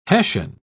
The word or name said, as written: hessian